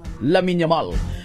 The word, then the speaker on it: Lamine Yamal: Lamine Yamal.